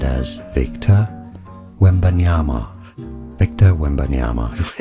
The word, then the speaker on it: Wembanyama: It is, say it with me, three syllables. Wembanyama.